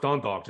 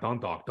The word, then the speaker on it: Dundalk: It's pronounced Dundalk. Just sound out Dundalk, Dundalk.